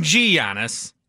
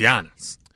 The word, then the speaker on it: Giannis: Also nice to talk to a national guy that knows how to pronounce Giannis. It ain't G Giannis. It's Giannis.